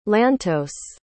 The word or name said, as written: lantos